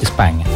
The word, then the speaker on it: Spagna: Se escribe Spagna, pero me dicen que es España.